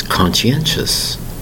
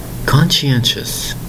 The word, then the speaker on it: conscientious: Today's word is conscientious. Conscientious. Four distinct syllables spelled C-O-N-S-C-I-E-N-T-I-O-U-S. Conscientious.